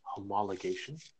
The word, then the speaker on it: homologation: before we go too much farther, there are some people out there who don't realize that the pronunciation is homologation. Or homologated, which is any road car that is based on an existing race car.